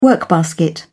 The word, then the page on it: workbasket: British English